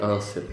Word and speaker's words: Özil: Är det Özil man säger? Är det uttalet? – Jag säger Özil. – Jag säger Özil.